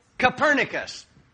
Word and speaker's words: Copernicus: I suppose it's as shocking and appalling as when Nicholas Copernicus, I can't remember how to say his name. Help me with that. What? – Copernicus.